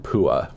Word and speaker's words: Puah: Puah from that.